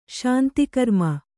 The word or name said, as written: ಶಾಂತಿಕರ್ಮ